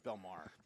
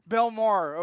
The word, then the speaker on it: Belmar: Ladies and gentlemen, we are broadcasted from the Colorado Media School here in Denver, Colorado, the Belfort area. You like that? Belfort area? – I thought it was Belmar. – Belmar. Okay, yeah.